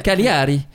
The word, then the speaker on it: Cagliari: Bah quand même, c'est que si je peux me permettre, on prononce pas le G en fait. – Cagliari.